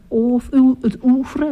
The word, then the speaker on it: oeuvre: Het is niet alleen een country band, maar een heel eerbetoon aan het oeuvre. Hoe zeg je dat? – Oeuvre.